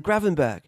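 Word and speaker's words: Gravenberch: If Christian Eriksen, say, never leaves, there is no David Klaassen, no Donny van der Beek, no Ryan Gravenberg, whose name doesn't end with a ch sound, by the way, he says. Just pronounce it as a G.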